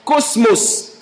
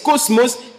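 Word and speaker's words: cosmos (κόσμος): c'est un mot grec que l'on prononce cosmos, K-O-S-M-O-S, cosmos, qui veut simplement dire une manière de penser générale.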